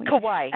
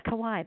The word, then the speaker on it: Kauai: I think I said that right. Kauai. – Kauai. – Kauai. Okay. Kauai. That's right. Sorry.